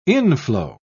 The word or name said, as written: inflow